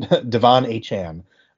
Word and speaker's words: Achane: I guess he pronounces it Achane. – I saw. – Even though he's been called Achane his entire college career and NFL career so far, it's now Achane after a big three touchdown game, I think it was.